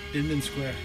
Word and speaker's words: Inman Square: Inman Square.